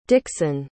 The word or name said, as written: dixon